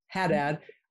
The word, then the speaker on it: Haddad: Yeah, Haddad, you got Haddad. – It. – Haddad, I don't know why I wanna say ha, it's like Nevada, Haddad.